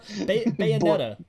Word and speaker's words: Bayonetta: I'm going to butcher this. Bonita? – Bayonetta, man. Bayonetta. Yeah.